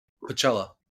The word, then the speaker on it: Pacella: So yeah, Tony Pacella, I say that right? – Pacella. – Pacella. I took Spanish in high school, so double L's is a Y for me.